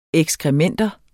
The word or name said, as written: ekskrementer